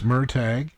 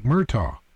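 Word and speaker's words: Murtagh: It doesn't look like that, does it? – Yeah, Murtag. – No, it looks like Murtaugh. Yeah. I would say that the GH is silent, so yeah. Murtaugh is yeah. how Murtag. I would pronounce it. – In the audiobook, it's Murtag.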